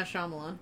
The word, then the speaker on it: Shyamalan: because it is an M. Night Shyamalan. That's the right way to say it, right? – Yeah, Shyamalan.